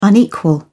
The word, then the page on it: unequal: British English